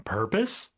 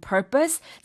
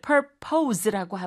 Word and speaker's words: purpose: 자, 이거 purpose라고는 발음하지 않습니다. – Right. – P-O-S-E로 끝나긴 하지만 비록 이걸 purpose라고 하면 안 되고요. 우리가 propose 할 적에는 os라고 하는데 purpose 같은 경우는 강세가 앞에 오기 때문에 어떻게 할까요? – Purpose. – Purpose. – Us, purpose. – Purpose, 그렇죠. – Purpose. – O 발음이 아니라 발음으로 purpose라고 하게 됩니다.